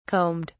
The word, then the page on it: combed: British English